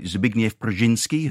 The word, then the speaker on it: Zbigniew Brzezinski: I'm not a foreign policy wonk by any stretch of the imagination, but, I know that, I hope I'm pronouncing it right, Zbigniew Brzezinski, who was Ronald Reagan's foreign policy advisor in the 1980s.